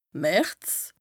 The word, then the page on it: März: Bas-Rhin; Strasbourg